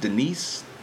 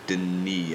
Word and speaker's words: Denis: Because I will say about this guy, I think it's pronounced Dennis. I look like it says Dennis to me, but I think he goes by Denis, if I'm not mistaken.